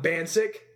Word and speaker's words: Bansic: I'm fine with that, but I'm Bansick.